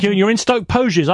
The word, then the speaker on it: Poges: I'm glad you've pronounced it Poges right again, yes.